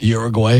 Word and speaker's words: Uruguay: Uruguay? – Is it Uruguay or Uruguay? – You said it right. – Either way. – Uruguay. – Say it fast. – Like Caribbean.